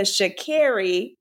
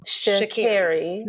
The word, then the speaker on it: Sha'Carri: So please, everyone, her name is Sha'Carri. Not Sha'Carri.